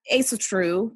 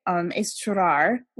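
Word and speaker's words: Ásatrú: So it's kind of funny because when I first sort of came across pagan and like neo-pagan paths, Esotru, or depending on how you say it, Esotrar, which is Northern paganism.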